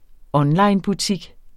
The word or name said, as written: onlinebutik